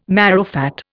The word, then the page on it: marrowfat: Indian